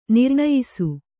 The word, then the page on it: ನಿರ್ಣಯಿಸು: nirṇayisu